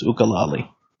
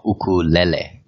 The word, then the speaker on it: ukulele: Some people sometimes think of the ukulele as a novelty, and I said the word wrong throughout the first time we did an interview with you, but it's actually pronounced ukulele, right? – Yeah, well, it's actually ukulele.